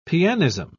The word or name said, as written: pianism